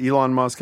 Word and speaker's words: Elon Musk: He talks about, is it Elon Musk, how you pronounce it? – Exactly.